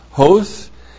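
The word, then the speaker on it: Hosts (ὅς): Oops, not long O. Just forget that. That's not a long O. That's Omicron, not Omega. H-O-S. Hosts.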